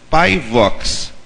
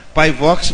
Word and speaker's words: PyVox: A primeira ferramenta chama-se PyVox. Né? PyVox não se escreve P aí, não. Se escreve PyVox. Por que fala Py? Py vem de Python.